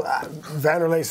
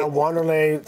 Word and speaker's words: Wanderlei: Well, Vanderlei Silva is what I was. – Vanderlei. – Wanderlei. Somewhere upon Wanderlei now, but I don't know. I go with Vanderlei Silva.